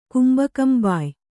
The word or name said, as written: ಕುಂಬಕಂಬಾಯ್